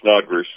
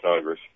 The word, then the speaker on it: Snodgres: Yeah, it's Snodgrass. That's how you pronounce it, Cody Snodgrass.